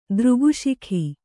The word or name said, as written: ದೃಗುಶಿಖಿ